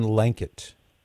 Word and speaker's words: Lankeit: Father John Lankett. – L-A-N... – K-E-I-T. – L-A-N-K-E-I-T. – So it rhymes with blanket.